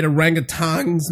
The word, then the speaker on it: orangutan: Orangutans? – It's orangutan. Orangutan. It's just not orangutan. There's no G.